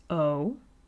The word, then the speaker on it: OOOOO: So the first track that I'm going to be playing is called Burnout Eyes by the artist O. That's how it's pronounced, but it's not how it's spelled. It's not spelled O-H. It's spelled O-O-O-O-O with varying degrees of capital and lowercase letters.